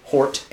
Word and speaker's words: Hort: Westcott and F.J.A. Hort, F.J.A. Hort, H-O-R-T. I don't know why I said that wrong.